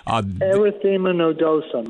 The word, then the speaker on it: erythema nodosum: I developed arrhythmia nodosum. I'm not sure if I said that pronounced. – Erythema nodosum. Erythema nodosum. Yeah.